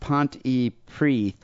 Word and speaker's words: Pontypridd: Well, I'm from Pontypridd, which I guess is pronounced Pontypridd, the birthplace of Tom Jones.